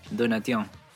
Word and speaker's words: Donatien: Eh, los practicantes me dicen que sí, lo pronunciaste correctamente. Donatien. – Donatien, Alphonse François. – exacto, media nasal. Exacto, claro, la N es ah, ah, como que la N te queda ahí en la nariz.